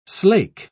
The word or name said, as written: slake